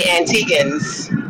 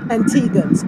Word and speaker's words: Antiguans: Is it Antiguans or Antiguans? – You say Antiguans. – Antiguans. Okay. I wanted to make sure. And the reason I asked, of course, is because of how it's spelled, right? A-N-T-I-G-U-A-N-S. And typically when you see G-U-A, it becomes gua versus ga.